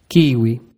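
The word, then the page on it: kiwi: Brazilian Portuguese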